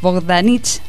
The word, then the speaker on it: Bogdanich: Rodrigo Bogdanich, lo dije bien, ¿no? – Sí.